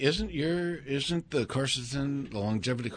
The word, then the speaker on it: quercetin: It's spelled Q-U-E-R-C-E-T-I-N. – Quercetin. Right.